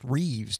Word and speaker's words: Rives: And it's spelled R-I-V-E-S, but it's pronounced Reeves, Tennessee. – Right. So it's spelled like Rives.